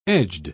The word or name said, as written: edged